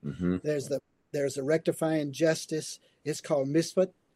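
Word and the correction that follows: mishpat (משפט): that's the that's pronounced mishpat.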